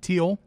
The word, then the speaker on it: Teal: We got Nyla Rose, Britt Baker, Allie, Brandi Rose, Is it Teal? – Teal. – Okay, like, I didn't know if she like pronounced it weird. – Like the color.